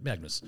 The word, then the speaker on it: Magnus: That's what I mean, like Magnus. – You said Mangus. – Did I? No, yeah. Magnus. It's Magnus.